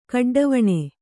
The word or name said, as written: ಕಡ್ಡವಣೆ